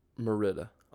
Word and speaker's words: Merida: But oh, sorry, how do you pronounce it? – Merida. – Oh, is that how you pronounce it? It's Merida.